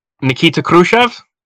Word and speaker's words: Khrushchev: Nikita Khrushchev.